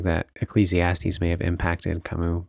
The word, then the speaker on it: Camus: I don't know if I'm saying his name right. It's French. – Yeah, that's Camus.